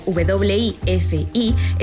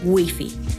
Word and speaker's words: wifi: En España, este préstamo del inglés, escrito W-I-F-I, está totalmente integrado, ya que la grafía inglesa se pronuncia a la española, esto es Wi-Fi o Wi-Fi. En el español de América, en cambio, se mantiene su pronunciación inglesa, Wi-Fi o Wi-Fi.